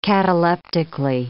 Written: cataleptically

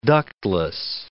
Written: ductless

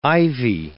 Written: ivy

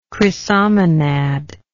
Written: chrysomonad

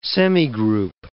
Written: semigroup

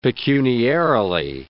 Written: pecuniarily

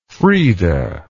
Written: Frieda

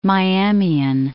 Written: Miamian

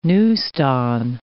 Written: neuston

